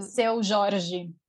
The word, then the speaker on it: Seu Jorge: ¿y qué te pasa a ti con cosas como, como cuando Seu Jorge, ¿cómo se pronuncia? Seu, Seu Jorge.